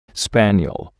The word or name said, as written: spaniel